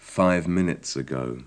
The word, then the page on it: five minutes ago: English